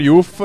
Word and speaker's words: Youth: La pronuncia per i non anglofoni, avvisa Favotto, è YOUTH.